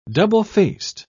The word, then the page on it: double-faced: British English